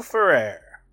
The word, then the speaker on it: Ferrer: Ferrer Rocher? – Miguel Ferrer.